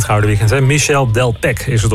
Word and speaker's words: Delpech: Ja, dat is net zoiets als Michel Delpech. Dan spreek je de H weer niet uit.